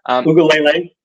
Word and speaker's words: Uiagalelei: I'll try to get the name right. DJ Uiagalelei, I believe. – Uiagalelei, yeah. – Okay, there we go.